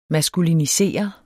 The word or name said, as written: maskulinisere